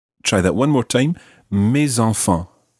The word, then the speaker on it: mes enfants: And it ends in an S, so normally the S is silent, but when it's followed by a vowel, it becomes mes, in this case, mes enfants.